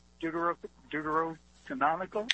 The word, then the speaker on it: Deuterocanonical: And my question is when, because I know the Deuterocanonical.